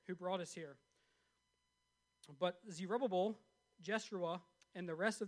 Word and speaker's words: Zerubbabel: they approached Zerubbabel." That's right. That's how you say it. I checked it out on YouTube. I practiced it 4 times.